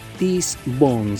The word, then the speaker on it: tesse.bon: ¿Cómo se escribe? @TESSE.BON. TESSE.BON. Tis Bon se pronuncia.